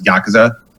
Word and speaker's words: Yakuza: and that's why like you're generally gonna hear it as Yakuza. – Yeah, like you barely even say the U.